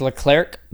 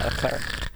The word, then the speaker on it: Leclerc: It's just Leclerc, but Charles Leclerc is Leclerc. How you say it. It's very French, yeah. Leclerc.